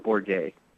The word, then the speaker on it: Bourguet: It's a tough one. It's French, but it's Gerald Bourge.